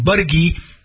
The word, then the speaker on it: Buttigieg: Buttigieg, that's the way they say his name, Buttigieg, he's now superseding.